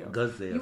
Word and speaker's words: gazelle: And that's in verse number 5 where she says, young women of Jerusalem, I charge you by the Giselles. Did I say that right? – Gazelle. We keep saying Giselle because we had Giselle in the office. – And I was saying Giselles then. – And it's Gazelle.